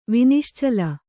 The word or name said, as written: ವಿನಿಶ್ಚಲ